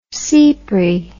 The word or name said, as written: sipri